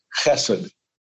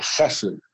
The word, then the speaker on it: chesed: So next week, we'll pick up with chapter 24, and the theme of that is chesed. It's guttural, pronounce it correctly, chesed.